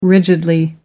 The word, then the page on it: rigidly: British English